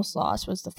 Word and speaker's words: Sloss: S-L-O-S-S.